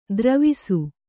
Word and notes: ದರವೇಸಿ: daravēsi

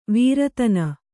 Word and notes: ವೀರತನ: Kannada

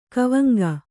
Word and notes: ಗವಾಂಗ: gavāŋga; Kannada

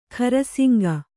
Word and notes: ಖರಸಿಂಗ: khara siŋga

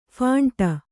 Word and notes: ಫಂಟ: phaṇṭa

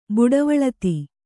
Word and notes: ಬುಡವಳತಿ: buḍavaḷati

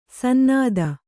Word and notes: ಸನ್ನತ: sannata

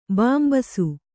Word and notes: ಬಿಂಬಿಸು: bimbisu

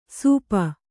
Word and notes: ಸೂಪ: kannada

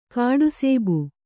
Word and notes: ಕಾಡುಸೇಬು: kāḍu sēbu

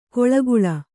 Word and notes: ಕೊಳ್ಗುಳ: kannada

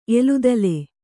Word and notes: ಎಲುದಲೆ: eludale

